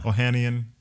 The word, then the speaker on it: Ohanian: Ohanian.